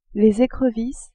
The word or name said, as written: ecrevisses